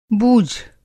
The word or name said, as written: बूझ